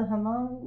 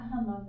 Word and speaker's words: Hmong: Right, is this the Hmong? Like the? – It's not Hmong, but it's Hmong.